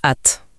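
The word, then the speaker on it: @: Et en prime, en anglais, ça se prononce at, donc chez.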